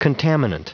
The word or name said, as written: contaminant